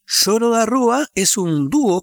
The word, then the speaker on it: choro: Nació en 2012 el deseo de difundir el lloro, que se pronuncia Zoro, en Italia y Europa para dar a todos la oportunidad de apreciar esta sofisticada música instrumental brasileña, buena para tocar y escuchar.